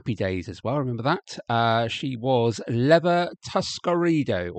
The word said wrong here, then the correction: Tuscadero: or Tuscadero. There we go. Get it pronounced correctly.